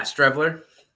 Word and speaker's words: Streveler: Matt Strevelier. Is that how you say it? – Yeah, Streveler.